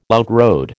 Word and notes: outrode: American English

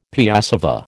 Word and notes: piassava: American English

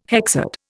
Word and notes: hexad: Indian

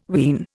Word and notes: ween: Indian

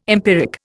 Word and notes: empiric: Indian

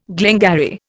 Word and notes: glengarry: Indian

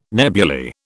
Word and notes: nebuly: British English

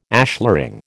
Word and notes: ashlaring: American English

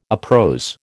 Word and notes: uprose: American English

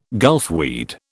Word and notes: gulfweed: British English

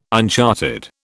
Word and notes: uncharted: British English

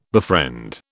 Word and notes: befriend: British English

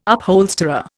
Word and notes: upholsterer: Indian

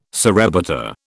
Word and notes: surrebutter: British English

